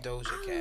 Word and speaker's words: Doja Cat: Doja Cat. – Doja Cat.